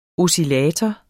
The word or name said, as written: oscillator